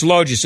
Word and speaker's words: Logis: We're talking to Rich Loges.